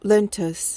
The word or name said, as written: lentous